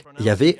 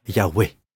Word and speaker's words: Yahweh: Cela se prononce donc Yahvé ou Yahweh.